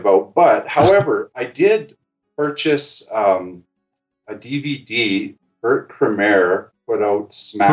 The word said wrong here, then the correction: Kammerer: Am I saying that right? – Kurt Kammerer. – Kammerer. – Okay. Kurt Kammerer.